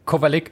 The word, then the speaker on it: Kovalik: Ich meine, er hatte ja diese kuriose Niederlage bei den French Open gegen Josef Kozelik, spricht man den so aus? – Kovalik.